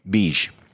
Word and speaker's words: Begue: Beige. Spell that. – B-E-G-U-E. Beige.